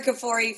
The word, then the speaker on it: Kfoury: It's actually Tara Kafori from River Educational Consulting. – Oh, sorry. – It's okay. No, it's okay. So my husband is Lebanese, and so the K and the F together is a very uncomfortable consonant pronunciation.